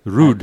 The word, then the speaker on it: Ruud: Ruud. – Yes, Ruud.